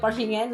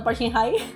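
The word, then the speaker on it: Park Shin-hye: Parshine, no se entera parece que se pronuncia Parshine, no Parshinhai.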